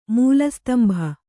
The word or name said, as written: ಮೂಲಸ್ತಂಭ